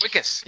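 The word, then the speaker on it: Wikus: In District 9, a low-level bureaucrat, is it, how do you say his name, Wickus? – Wickus, yeah, Wickus.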